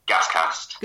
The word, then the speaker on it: Gascast: Gascast. Or is it Gascast? How do you pronounce it in the West Country? – Gascast.